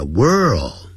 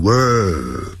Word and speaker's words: world: 世界那个字叫 world，因为有一个 r 还有一个 l，所以先念成卷舌音 world，然后那个 l 呢翻成欧洲的 o， world，就 world，好，那个 d 念得很弱，好， world， World War II，好，就是第二次世界大战。